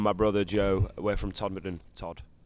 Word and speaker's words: Todmorden: People in the UK can't even pronounce where it is. It's just called Todmorden.